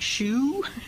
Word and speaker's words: shew: And you notice the word show in the King James show, S-H-E-W. Have you ever wondered about that funny little word? Show. How do you really pronounce it? Shoe? Shoe, really, isn't it?